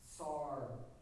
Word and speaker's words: tsar: T-S-A-R, pronounced SAR.